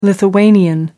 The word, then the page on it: lithuanian: American English